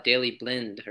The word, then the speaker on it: Daley Blind: The prominent one I know of is Daley Blind, or however you say his name. I feel like it's pronounced. – You were right. I'm pretty sure you were right there.